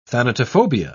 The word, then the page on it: thanatophobia: British English